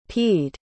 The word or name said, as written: peed